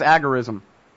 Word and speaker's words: agorism: Tell me what's agorism. Or am I even saying it right? – Yeah, sure, that's the right way to say it.